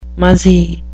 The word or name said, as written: muzzy